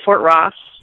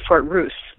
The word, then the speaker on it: Fort Ross: And they're actually Fort Ross yeah. – Is actually Fort Ruse. That's how you actually pronounce it. I didn't realize that.